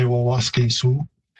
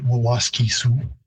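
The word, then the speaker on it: Waskesiu: So the pronunciation isn't too far off. In Plains Cree, I'd say Waskasu. And in Woods Cree, which is the territory where it's located, Waskisu.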